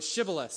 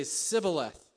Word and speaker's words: Shibboleth: But they couldn't pronounce the word Shibboleth, so they would say Sibboleth.